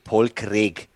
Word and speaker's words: Craig: Paul Craig haben sie, mal, haben sie mal gefragt, wie spricht man seinen Namen aus? Und er gemeint, Paul Craig.